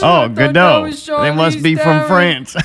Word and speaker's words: Gadot: No, you pronounce it. It's French. It's Gada. – It's not French, it's Gadot. Jewish, man. – Gadot. Oh. It is pronounced Gadot.